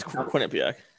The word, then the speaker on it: Quinnipiac: He was the captain of his uh, NCAA team in Quinnipiac, or I'm saying that wrong. yeah, yeah, no, that's Quinnipiac.